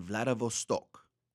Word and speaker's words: Vladivostok: Vladivostok.